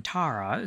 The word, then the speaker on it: Tara: Another totally unrelated fun fact, the A in Tara is pronounced the same as FARA, the acronym for the U.S.